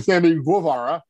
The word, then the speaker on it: Sammy Guevara: Sammy Guevara.